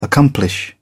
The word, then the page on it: accomplish: British English